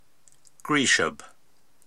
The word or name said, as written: Griishab